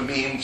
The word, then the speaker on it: meme: the meme to rhyme with gene.